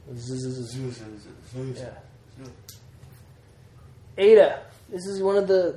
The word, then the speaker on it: Eta: It's a long E sound. A, as in obey. – Like it's our normal A. Eta.